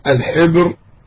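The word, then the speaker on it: الحِبر: وأما قوله الحبر و تنطق بكسر الحاء الحبر، وهو كبير العلماء، ولذلك يقال لعلماء أهل الكتاب أحبار.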